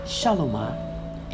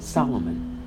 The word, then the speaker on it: Shalomah: The Proverbs of Shalomah, in your Bible, we Christians, we pronounce it Solomon. We pronounce it Solomon, but it's Shalomah.